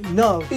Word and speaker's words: Nav: I go by Nav. It rhymes with love.